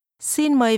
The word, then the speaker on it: xin mời: The first part is pronounced with a mid-level tone. – Xin. – And a falling tone.